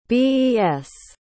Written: Bes

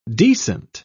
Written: decent

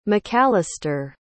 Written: macalaster

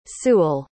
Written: sewall

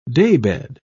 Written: daybed

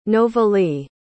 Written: novalee

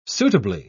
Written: suitably

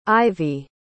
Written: Ivie

